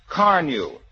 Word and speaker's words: Carnu: Remember the name, Carnew. It's spelled C-A-R-N-U, and it's made by the makers of Johnson's Wax.